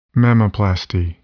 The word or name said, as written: mammaplasty